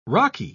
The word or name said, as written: raki